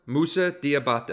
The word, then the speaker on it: Musa Diabate: How do I pronounce Moose's name here? – Musa Diabate.